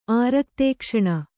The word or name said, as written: ಆರಕ್ತೇಕ್ಷಣೆ